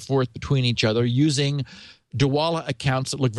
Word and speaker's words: Dwolla: D-W-O-L-L-A.